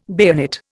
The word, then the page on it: bayonet: Indian